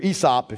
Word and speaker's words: Aesop: It's not Aesop's fables. Or Aesop, if you pronounce it properly.